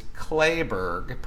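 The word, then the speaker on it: Kleberg: Jay Clayburgh, his name is spelled K-L-E-B-E-R-G. I thought it was Clayburgh for a long time. It is Clayburgh, pronounced C-L-A.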